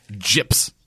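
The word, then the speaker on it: JP's: Gyps.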